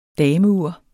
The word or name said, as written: dameur